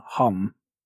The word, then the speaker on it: hum: In the Aetherius Society, they pronounce the last one hum. – Okay, yeah. – Which was difficult because it's normally om again.